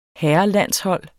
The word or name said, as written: herrelandshold